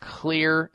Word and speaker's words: Xlear: I like one called Clear Nasal Spray, and that's spelled X-L-E-A-R. The X is for xylitol.